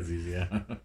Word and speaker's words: ZZ Top: It's funny because in the Wikipedia page it says it's pronounced Z-Z-E-E-Z-E-E. – Yeah, Z-Z, yeah.